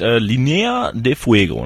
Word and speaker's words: Linea de Fuego: Sag nochmal genau, wie es sich jetzt ausspricht. Linea de Fuego, ne? – Genau, du hast es perfekt ausgesprochen.